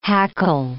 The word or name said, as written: hackle